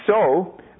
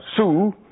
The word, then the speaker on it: sew: S-H-E-W. But don't forget, that's the same way we spell the word sewing. When ladies sew, that's S-E-W, but we don't pronounce that sue. Women don't sue when they make a dress. They sew.